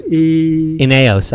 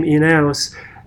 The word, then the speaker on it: Ineos: He's the Ineos, how you say it? – Ineos, I think is how you say it.